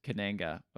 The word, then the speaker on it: Kananga: And then Kanaga takes Bond's watch and then says, okay, on the back of the watch, it says Kananga. – Okay. – We've been saying it wrong this entire fucking time, I think.